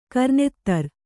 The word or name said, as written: ಕರ್ನೆತ್ತರ್